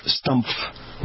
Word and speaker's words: Stumpf: Stumpf. Which rhymes with Trump.